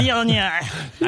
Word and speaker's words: Viognier: Yeah. Viognier. – I said it right. – Yeah, that's exactly right.